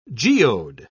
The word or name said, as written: geode